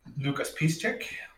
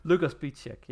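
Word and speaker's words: Łukasz Piszczek: Lukas Piszczek. – Damn, I should make a clapping band. Like Lukas Piszczek, yes.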